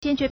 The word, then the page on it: 坚决: jiānjué